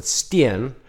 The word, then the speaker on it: Steen: Steen, or as I would pronounce it, Steen, which means stone in Dutch and Afrikaans.